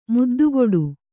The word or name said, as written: ಮುದ್ದುಕೊಡು